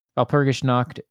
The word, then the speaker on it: Walpurgisnacht: For any listeners who don't want to look it up, Walpurgisnacht am I saying that right? – Yeah.